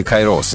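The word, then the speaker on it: кайрос (καιρός): Это хронос и кайрос, или кайрос, если правильно. Хронос и кайрос, ударение будем ставить правильно.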